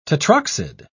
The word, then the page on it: tetroxide: British English